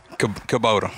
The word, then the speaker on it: Kubota: Yeah, so well, now you got, how do you pronounce it, Kubota? – Kubota.